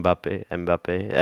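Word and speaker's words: Mbappé: Mbappe.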